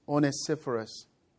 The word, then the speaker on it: Onesiphorus: His name is Onesiphorus.